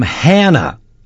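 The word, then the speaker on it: Hanna: A band from a town that rhymes with banana. And no, it's not Havana. This is Nickelback from Hannah Banana.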